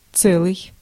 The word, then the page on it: целый: [ˈt͡sɛlɨj]; /ˈt͡sɛ.liː/